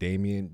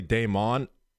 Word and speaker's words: Damon: They will D-A-M-E-N-D-A-M-I-O-N, like Damien, D-A-M-I-E-N. I tell people my name is Damon all the time, and after having heard it, they still call me Damien.